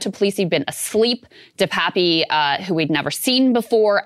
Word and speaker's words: DePape: DePape. – Himself. – DePape.